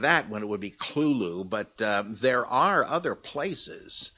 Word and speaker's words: Cthulhu: The U is about like that in full, and the first syllable is not unlike klul, K-L-U-L, in sound. Hence, the H represents the guttural thickness. S.T. Joshi, the great authority on Lovecraft, uses this pronunciation. He speaks of klulu.